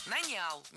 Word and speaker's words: нанял: Нанял. Неверно. Правильно, нанял. Ударение в этом слове падает на первый слог во всех формах прошедшего времени, кроме женского рода. Он нанял, оно наняло, они наняли, но она наняла.